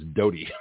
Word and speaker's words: Doughty: I heard someone else pronounce it Doty. It's D-O-U-H, D-O-U-G-H-T-Y. Uh, usually the southern name around here is Daughtry, but there's no R in there, so it's Doty.